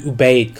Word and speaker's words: Ubaid: And the pronunciation on that is not 100%. I've heard it pronounced both ways, but most people that I've heard actually talk about it use the term Ubaid.